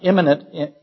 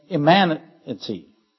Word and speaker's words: immanency: Not imminent, immanency. I have to emphasize the correct syllable.